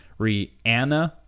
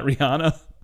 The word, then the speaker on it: Rihanna: It's Rihanna, not Rihanna. Huge difference.